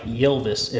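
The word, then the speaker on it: Ylvis: No, they're called, it's like Elvis, but the E is with the Y. Like Yelvis, if you're saying it phonetically.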